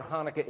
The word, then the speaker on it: Hanukkah: The first letter in the word Hanukkah is the chet, the Hebrew letter chet. You pronounce the Hebrew letter chet as in there's no English equivalent. And it really, most properly, is pronounced Hanukkah. But everybody says with a slight Hanukkah. Some people just say Hanukkah with an H, but it's really Hanukkah.